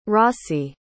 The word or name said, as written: rossie